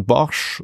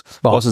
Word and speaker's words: Bosch: the time was difficult for the church, not actually at the time when Bosch, or Boss, Boss is the correct pronunciation right. in Netherlandish language.